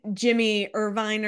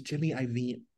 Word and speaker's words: Jimmy Iovine: I've been saying Jimmy Irvine or something, everyone, and it's Jimmy, I like IOVIN. – Oh, it's Jimmy Iovine.